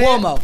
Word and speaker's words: Cuomo: How do you say Cuomo. – It? – Cuomo. – Like Governor Cuomo.